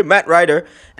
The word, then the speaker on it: Matt Ryder: Yay, Matt Ryder.